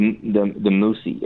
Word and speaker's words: Muxi: And, you know, just in Alcoke, yeah, the Moosey, it's spelled M-U-X-I.